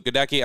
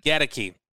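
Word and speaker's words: Goedeke: You've got the rookie, Luke Gedeki. Actually, I don't think that's how you pronounce it. You tell me, what's the pronunciation on Luke? – Gedeki.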